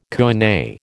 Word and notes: koine: American English

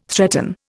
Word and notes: threaten: Indian

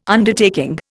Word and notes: Undertaking: Indian

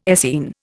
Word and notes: Essene: Indian

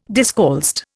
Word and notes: discalced: Indian

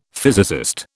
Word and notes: physicist: British English

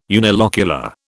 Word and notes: unilocular: British English

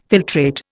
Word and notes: filtrate: Indian

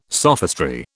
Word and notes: sophistry: British English

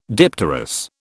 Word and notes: dipterous: British English

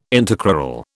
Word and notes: intercrural: British English

